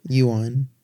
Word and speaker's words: yuan: The biggest Juan. Sorry. I probably mispronounced it too. – Yuan. – Yuan. Oh. – Not Juan, idiot.